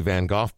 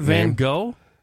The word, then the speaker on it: Van Gogh: It's not Van Gogh? – I believe that in recent years it has been modified for the Amsterdamian Netherlanders pronunciation of Van Gogh.